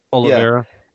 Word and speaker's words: Oliveira: Oliveira.